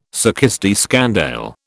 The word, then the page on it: succès de scandale: British English